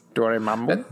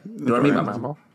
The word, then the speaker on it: Dormammu: Dormammu. – That's how you say it. – Dormammu.